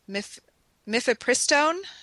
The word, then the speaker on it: mifepristone: And so you can have postcoital copper IUD placement, combined oral contraceptive, progesterone only options, or mifepristone. Am I saying that correctly? – Mm-hmm. Yes.